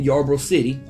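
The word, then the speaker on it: Ybor City: And this is in Yarborough City. I want to say I'm pronouncing that correctly. That is correct. – Yarborough City.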